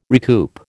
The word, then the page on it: recoup: American English